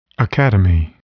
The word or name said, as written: academy